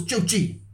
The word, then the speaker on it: Zhou Qi: No, that was the Rockets guy, the Chu Qi or Qi. I don't, it's Chinese. I'm not sure exactly how to pronounce this. Z-H-O-U-Q-I. Those are two names. – Yeah. – Oh, it's Zhou Qi. – Yeah, Zhou Qi.